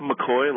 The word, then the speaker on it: McKay: It's just like McCoy, like McCoy, but it seemed like you were pretty close.